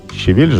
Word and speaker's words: щавель: Вот и сейчас я закрываю щавель. Щавель же, да? Я все время забываю, куда надо здесь ударение ставить.